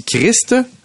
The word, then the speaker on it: Christ: Le petit Christ, hein, c'est bien important de prononcer le T, toi qui es bien connu dans ton catéchisme.